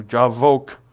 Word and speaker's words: Jabbok: or Jabbok, though the B is pronounced like a V in the Hebrew, Jabbok.